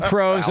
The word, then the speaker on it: pros: Oh, uh, two pros and a cup of Joe. Uh, the uh, the B is silent there.